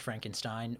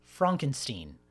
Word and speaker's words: Frankenstein: On that note, he insists that his surname is not pronounced Frankenstein, but is indeed pronounced Frankenstein, mm-hmm. Which sounds more, way more German.